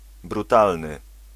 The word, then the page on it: savage: /ˈsævɪdʒ/